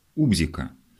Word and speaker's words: убзика: Товарищ моего детства, писатель Борис Житков, рассказывал мне, что в 3-летнем возрасте он выдумал слово «убзика» с ударением на «у» и долго боялся глядеть вечерами под отцовский диван, потому что сам же уверил себя, будто там прячется эта страшная «убзика».